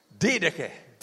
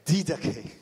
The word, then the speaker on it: Didache: How do you say this again? – Goodbye. – Goodbye. I love it. How about this? – Didache. – Didache. I love that.